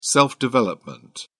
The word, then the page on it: self-development: British English